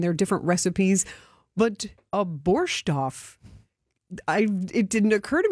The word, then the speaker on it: borscht: So in Ukrainian, we usually say borscht.